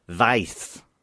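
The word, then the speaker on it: Weiss: I thought, yeah, I was picturing W-I-S-E, like Pennywise. – No, E-I-S-S. – Sound, I reckon that would even be pronounced something like Vice. – Probably. Yeah, it's not like Rachel Vice.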